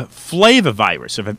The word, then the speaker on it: flavivirus: Now, being based in South Florida, those in South Florida are familiar with something called a flavivirus, if I pronounced that correctly, and that would correct.